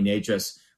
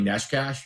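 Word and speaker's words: Nečas: It was like that game in the playoffs against Nashville a couple years ago, where I think it was Ken Cal kept calling Marty Natchez Marty Neshkash.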